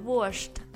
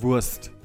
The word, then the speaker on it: wurscht: Ah, 对，就是大家刚刚也听到了，我们就说的是 es ist mir wurscht mit sch 啊。 – Genau, man sagt nicht es ist mir Wurst.